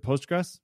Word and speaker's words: Postgres: Is it Postgres? Yep, that'll do.